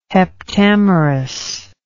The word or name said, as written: heptamerous